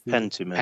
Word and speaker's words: Pentiment: They're going to be showing that off, third-party titles, but also their games like, how do you say it? Pentiment? – Which is Pentiment. – Thank you. – Pentiment.